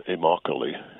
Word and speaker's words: Immokalee: in the town of Immokalee. It's pronounced like broccoli, Florida, which is the largest migrant worker community in Florida.